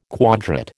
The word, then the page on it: quadrate: American English